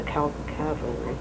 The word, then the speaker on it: cavalry: He was in the First World War in the Canadian Army, in the cavalry. I never say that right. Cavalry, not the cavalry.